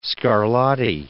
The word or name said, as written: Scarlatti